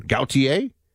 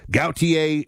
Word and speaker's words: Gautier: It's definitely French. Is that, you know, an origin? Gautier? Like Jean-Paul, almost like Jean-Paul Gaultier, although people are going to be like, how dare you?